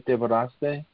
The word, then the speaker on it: Devoraste: If I'm saying that right, Devoraste? – Yeah, definitely. You're saying it right.